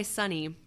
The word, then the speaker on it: Sunny: I go by Sunny. – And which is spelled S-U-N-N-Y.